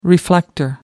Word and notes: reflector: American English

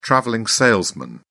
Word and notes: travelling salesman: British English

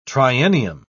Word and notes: triennium: British English

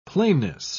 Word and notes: plainness: British English

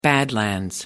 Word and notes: badlands: American English

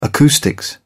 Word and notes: acoustics: British English

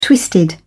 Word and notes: twisted: British English

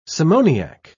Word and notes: simoniac: British English

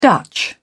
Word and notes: dutch: British English

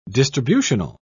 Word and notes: distributional: British English

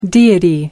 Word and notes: deity: American English

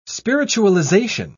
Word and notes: spiritualization: British English

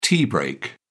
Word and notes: tea break: British English

